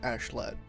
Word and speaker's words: Askeladd: It's spelled like Askeladd, but they pronounce it like Ashlad.